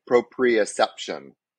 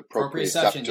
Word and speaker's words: proprioception: And so there's, I always forget how to say this correctly. So you might have to remind me, proprioception, the proprioception. Yeah. You said it right.